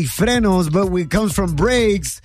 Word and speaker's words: frenos: The right way to say it, apparently, is frenos.